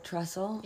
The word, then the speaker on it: Trussell: Like Russell, but Trussell? – Yeah.